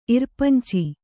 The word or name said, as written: ಇರ್ಪಂಚಿ